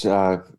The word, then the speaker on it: Ojai: Yes, it's Ojai. – Ojai. Ojai. Okay. – It's spelled O-J-A-I. So don't say Ojai. – Ojai. Ojai, yeah. – Gotcha. – You run out of town by the locals if you do that.